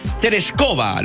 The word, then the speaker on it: Tereshkova: En 1963, Quique Bravo nos recuerda que Valentina Tereshkova, lo dije bien, ¿no? – Sí, está bien.